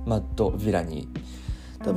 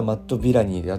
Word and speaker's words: Madvillainy: マッド・ヴィラニー多分読み方マッド・ヴィラニーで合ってると思うんですけど昔マッド・ヴィレイニーだと思ってたんだけど調べ直したらマッド・ヴィラニーだそうですえっ、